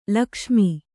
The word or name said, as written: ಲಕ್ಷ್ಮ